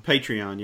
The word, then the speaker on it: Patreon: And so he said one thing he's doing, and he's hoping the fans will get with him on this, is correct me, if I'm saying this incorrectly, but Patreon? – Patreon, yeah.